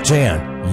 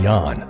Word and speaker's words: Jan: Ladies and gentlemen, Jan Hammer, not Jan, Jan, okay? Make sure you pronounce that right.